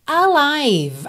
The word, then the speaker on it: alive: A-L-I-V-E. O E final não é pronunciado. E esse I do meio também é o I longo ou aberto, como um ditongo, I, ok? Então, alive, alive.